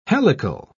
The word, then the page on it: helical: British English